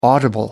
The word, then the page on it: audible: American English